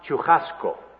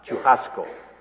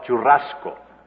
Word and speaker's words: churrasco: It has a double R, and it's pronounced churrasco, a hard aspirated H sound, churrasco. In Spanish, we call it churrasco, the trilled R.